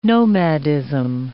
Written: nomadism

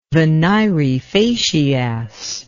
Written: venire facias

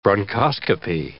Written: bronchoscopy

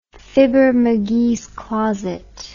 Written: Fibber McGee's closet

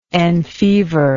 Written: enfever

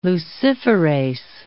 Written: luciferase

